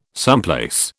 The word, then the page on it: someplace: British English